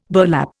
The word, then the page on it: burlap: Indian